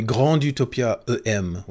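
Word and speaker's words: Grande Utopia: It's called the Grand Utopia EM, or Grand with an E, so it's probably Grand Utopia EM would be the way it's pronounced.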